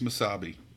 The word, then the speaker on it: Mesabi: state is the Misabi Iron Range? I don't know if I'm pronouncing that correctly. It's M-E-S. – Yes, it's Miss Misabi. – M-E-S-A-B-I.